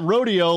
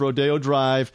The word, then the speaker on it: Rodeo: Not Rodeo, like we were talking about earlier, even though it's spelled the same. There's Rodeo Drive.